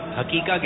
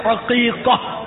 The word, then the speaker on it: عقیقہ: اچھا امیر سنت کی بار کے مرد کچھ لوگ حقیقہ کہتے ہیں, حقیقہ اس کا صحیح تلفظ کیا ہے؟ – حقیقہ. – ہاں سے عین سے, عین سے عقیقہ.